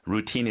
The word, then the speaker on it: routine: Routine is obviously English. – 네. – 우리말로도 루틴이라고 표현하는데. – 루틴. 네. – 근데 발음이 액센트가 뒤에 있어요. Routine.